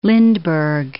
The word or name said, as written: Lindbergh